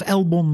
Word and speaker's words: Alex Albon: En ik vind uitspraak, ja, ik zeg Alex Albon. En daar is op zich niks mis mee. En als je vindt dat het Albon moet zijn of Elbon moet zijn, ja, dat mag van mij ook.